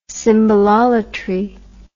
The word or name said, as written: symbololatry